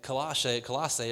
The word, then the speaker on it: Colossae: in Colossae is how you say it.